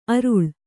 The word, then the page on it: ಅರಳು: araḷu